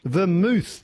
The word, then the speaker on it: vermouth: So it's a type of drink that ends with the letters of mouth, but is not pronounced mouth. It's pronounced mooth. What is it that Cinzano bottles? – Vermouth is what I'm looking for.